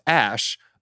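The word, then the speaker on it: Æ: A-E is pronounced Ash.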